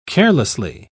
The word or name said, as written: carelessly